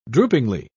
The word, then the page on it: droopingly: British English